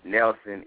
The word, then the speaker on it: Nelson: My name is spelled ALCSTOM UAWN, Nelson, NELSON, and all of my social media come up.